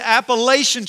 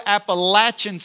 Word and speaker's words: Appalachian: Like I would love to hike the Appalachian Trail, which by the way, I've called it Appalachian Trail my entire life, and David James, our worship pastor, went to school at Appalachian State, and I learned that the right way to say it is Appalachian, so I just learned that last week, so maybe some others learned too, right?